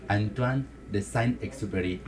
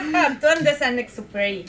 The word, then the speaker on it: Antoine de Saint-Exupéry: Antoine de Saint-Exupéry. ¿Lo dije bien, Nora? – Perfectamente. ¿Mi francés está bien? Está muy bien. – Antoine de Saint-Exupéry.